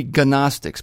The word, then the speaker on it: Gnostics: They're so evil that they don't pronounce the G at the beginning of their name. G-N-O-S-T-I-C-S, and the G is silent. That's, oh boy. That's evil, if you ask me. It should be Gnostics, but no.